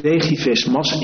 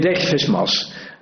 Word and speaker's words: Degivis-Mas: Meneer Degis, Degivis-Mas, ik moet dat toch netjes blijven uitspreken om u recht te doen. Meneer Degivis-Mas, Democraten Hilversum, gaat u gang.